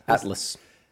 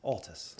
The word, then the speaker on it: Altus: Yeah, it's been butchered on Golf Channel 2 weeks in a row. So if anybody's listening, what do they call it on golf? – Atlas. – Yeah, they called me from Atlas Performance. And they said, because Marty Doe, one of Cameron's clients, won last week. And then they had one of my clients mic'd up this week. So, yeah, Altus.